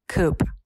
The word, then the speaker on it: coupe: It's not coupe, it's coupe. – Coupe. – Cute. – Yeah, well, there's an E at the end, and it's certainly not coupe, which we felt like would be the only other option.